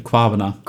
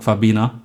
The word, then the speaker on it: Kwabena: Ich kann den Namen nicht aussprechen. Wie heißt der? – Kwavna. – Kwavna. – Auf Deutsch würde man sagen Kwabena.